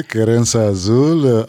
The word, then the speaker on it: Kerensa Azul: Qui s'appelle Kerensa Azul, qui veut dire, je prononce bien? – Oui, oui, Kerensa Azul, amour azur, cœur azur.